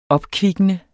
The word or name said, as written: opkvikkende